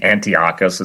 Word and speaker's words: Antiochus: By the way, I pronounce his name Antiochus. It sounds better, in my opinion.